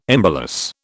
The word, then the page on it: embolus: British English